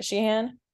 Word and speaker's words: Sheehan: It's Dan Sheehan. – Hello. – Am I saying that right? Sheehan? – Yeah.